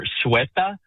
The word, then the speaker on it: Ceuta: It's the town of, and I believe I have the pronunciation here, Ceuta. C-U-E-T-A.